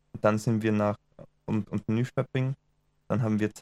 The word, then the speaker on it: Nyköping: Damals dachte mir noch, es heißt Nyköping, aber K-Ö wird schö ausgesprochen. Nyköping. So wie Köttbullar, nicht Köttbullar beim IKEA.